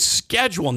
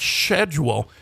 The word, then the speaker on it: schedule: In this case, I do agree with them because it's not a silent C. It's schedule, not schedule. And so the C in there. It's not silent.